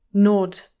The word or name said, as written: nod